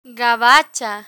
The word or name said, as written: gabacha